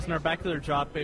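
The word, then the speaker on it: Narbacular Drop: Is it Nerbacular Drop? I can never pronounce it. – Right, right. My senior game project was Nerbacular Drop.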